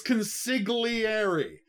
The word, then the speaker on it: consigliere: And then he's like, please, it's consigliere. Say it right.